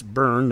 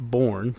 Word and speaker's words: Byrne: Coach Osborne, and it's Byrne, not Born, since we're getting things correct with the Ferentz, it's Osborne.